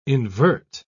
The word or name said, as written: invert